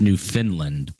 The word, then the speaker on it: Newfoundland: Newfoundland, Canada. We have a new member. – It's pronounced New Finland, but yes, it is.